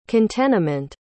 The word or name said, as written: contenement